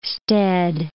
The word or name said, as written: stead